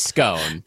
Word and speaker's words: scone: Okay, so I say it's scone, personally. – I say scone, too. But some people say scone. – Some people say like, scone, right? – Like rhymes with swan.